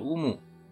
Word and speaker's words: 有無: 这边有一个单字，汉字写作有无，念作乌姆啊，这很容易理解。